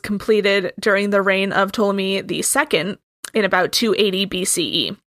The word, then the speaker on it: Ptolemy: It's it's spelled Ptolemy, but yeah, it's a PT name. Experience has has taught me that the P is not pronounced generally.